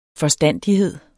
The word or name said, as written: forstandighed